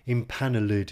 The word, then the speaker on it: empanelled: And note, of course, that empanelled here is pronounced in four syllables.